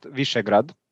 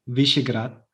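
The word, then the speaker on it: Višegrad: It's so the town is pronounced Visegrad. Visegrad. Uh-huh. Yes, and Grad means actually town.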